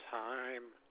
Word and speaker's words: Heim: David, last name is Heim, H-E-I-M.